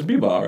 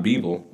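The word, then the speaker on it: Bivol: It's B-Ball, right? – Yeah. That's how I say it. – Yeah. – Right, man. – I know it's not Beeble.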